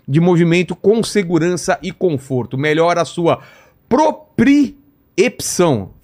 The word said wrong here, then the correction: propriocepção: Falei certo? – Propriocepção.